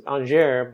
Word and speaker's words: Angers: And then Robert and I, and as a side note, Robert's last name is Angier, but it's spelled Angers.